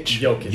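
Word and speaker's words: Jokić: And, I love how you pronounce it, Jokic? – Jokic.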